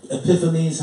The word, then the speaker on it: Epiphanes: Antiochus Epiphanes was, Epiphanes, however people pronounce it, it's Epiphanes, I'm telling you that.